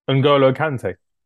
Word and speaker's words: N'Golo Kanté: I love the way Kante from I'm not sure if I'm saying his name right. From France. – N'Golo Kanté. – Yeah, I Yeah.